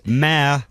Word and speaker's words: mayor: It's not mayor. – It's not. It's mare. – Yeah, not even West Country people say mayor. – Mare is a horse. – No, female horse. – Wrong. – Spelt differently, but pronounced the same.